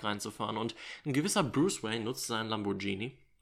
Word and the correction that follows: Lamborghini: Lamborghini, wie kann man das so schlecht aussprechen? Lamborghini.